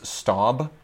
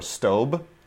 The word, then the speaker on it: Staub: We use Staub or Staub, depending on how you pronounce it, STAUB, which is better than La Creuset.